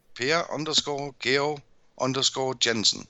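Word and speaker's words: Per Georg Jensen: It's my name, Per_Geo_Jensen.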